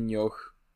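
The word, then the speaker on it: Nioh: Więc tak, przede wszystkim nie Nioch, tylko Nio, a po drugie... – Nio? Jest... – Eee, Nio. H jest nieme?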